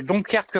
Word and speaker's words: Donkerque: Oui, alors je suis monsieur Donkerque Pascal. – Ça s'écrit comment le nom? – D-O-N-K-E-R-Q-U-E.